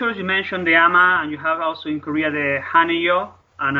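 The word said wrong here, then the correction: Haenyeo: I think it's Hainyo.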